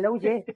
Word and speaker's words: Lou Ye: Sí, no, bien, bien, Lou Ye.